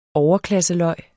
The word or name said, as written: overklasseløg